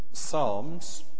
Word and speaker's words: psalms: Now, you may have noticed that we're in a series on some psalms, Psalmen in German, in case you don't recognise the fact that the P is silent in English.